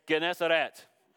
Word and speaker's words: Gennesaret: It says, one day, as Jesus was standing by the lake of Gennesaret, the people were crowding, and that's how you pronounce it, believe it or not. I went to the Strong's G, and it's Gennesaret.